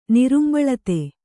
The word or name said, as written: ನಿರುಂಬಳತೆ